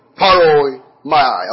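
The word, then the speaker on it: paroimia: So the word here is paroimai. I'll spell it for you. P-A-R-O-I-M-I-A. P-A-R-O-I-M-I-A.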